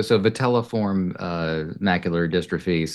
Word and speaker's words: vitelliform macular dystrophy: Yes, so vitelliform macular dystrophy.